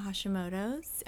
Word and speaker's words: Hashimoto's: We're excited to have you today and learn all about Hashimoto's. Am I saying that right? – Yes. – Yes. Okay. – That's correct.